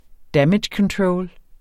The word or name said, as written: damage control